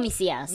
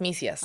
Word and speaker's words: Misias: De hecho, la cuenta Misia, ¿es Misias o misías. Misias, Misias.